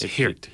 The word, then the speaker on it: Geert: his name is spelled G-E-E-R-T, but we found out the G is kind of silent, isn't it? – It's Geert. – Geert Wilders, yes.